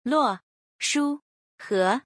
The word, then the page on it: 洛书河: Luo